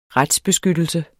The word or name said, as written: retsbeskyttelse